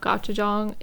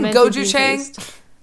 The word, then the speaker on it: gochujang: We have, like, a really, really old jar of gochujang, and I keep telling Brian it's fine, because it literally is fine. – Are you saying gochujang? – Yeah, I'm saying it wrong. I'm saying it so wrong.